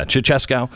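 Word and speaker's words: Ceaușescu: And she was working as a young attorney under Ceausescu. Is that how you say his name, Pat? – Yeah. – Yeah, Ceausescu.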